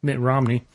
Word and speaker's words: Mitt Romney: Mitt Romney.